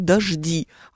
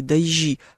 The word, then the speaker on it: дожди: Дождь, но при этом, кстати, если уж мы будем э, ориентироваться на вот эту старую норму произношения, то он, конечно, дождь, а когда их много, то они дожди, а не дожди, как может кому-нибудь показаться на всякий случай.